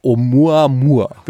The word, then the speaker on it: Oumuamua: Der Name ist von, das ist ein hawaiianischer Name, Oumuamua. – Oumuamua. So? – Oumuamua. – Hast du die richtige Aussprache auch? Ja, O-U-M-U-A-M-U-A. Amua.